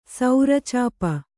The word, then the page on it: ಸೌರಚಾಪ: saura cāpa